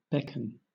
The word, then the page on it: beckon: British English